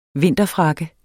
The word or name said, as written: vinterfrakke